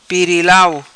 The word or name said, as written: Pirilau